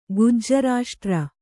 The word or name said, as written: ಗುಜ್ಜರಾಷ್ಟ್ರ